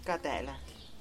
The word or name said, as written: kadeLady